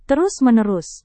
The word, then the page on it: terus-menerus: indonesian